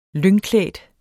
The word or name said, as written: lyngklædt